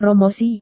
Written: promosi

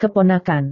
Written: keponakan

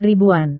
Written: ribuan

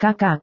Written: kakak

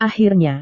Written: akhirnya

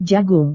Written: jagung

